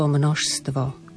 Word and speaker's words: množstvo: Z hľadiska výslovnosti pozor na slovo množstvo. Vidíme, že máme za sebou 4 spoluhlásky. Množstvo znamení a divov. Opäť, tak ako sme mali cirkev, krv, prv, opäť na konci slova V sa číta ako U.